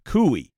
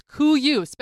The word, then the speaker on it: Kuiu: And it's not Kui. – No, it's Kuyu. – I always thought it was Kui. – It's spelled K-U-I-U.